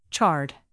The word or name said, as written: charred